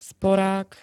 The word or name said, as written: sporák